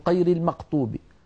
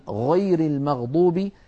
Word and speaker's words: غير المغضوب: يعني مثلا بعض أهل تلك المناطق يقولون قير المقطوب قير المقطوب بالفاتحة بدلا من, بدلا من غير المغضوب يشوبون صوت الغين بشيء من القاف